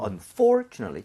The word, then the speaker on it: unfortunately: 발음할 때 여기 강세를 찍어주고요. Unfortunately. 그리고 여기 lately가 아니라 뭘까요? – Nutley. – Notly. 그래서 약간 not 비슷하게 제가 보여드릴게요.